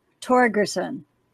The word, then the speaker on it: Torgerson: It's a Norwegian name. I'm Carla Torgerson. So a hard G.